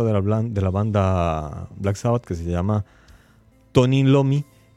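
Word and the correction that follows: Tony Iommi: eh, perdón, Tony Yomi, ¿cómo me cuesta pronunciar dos y seguidas? Tommy Yomi, Tony Yomi, él notó que a pesar de que las películas eran de miedo, sí tenían siempre mucha audiencia.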